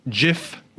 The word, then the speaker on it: GIF: Now, when the GIF was created in 1987, the creator, Steve Wilhite, pronounced it GIF with a soft G.